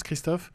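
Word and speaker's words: Krzysztof: Le chef-d'œuvre spirituel du mois, c'est donc Tu ne tueras point, de Christophe, c'est comme ça qu'on prononce Christophe, oui.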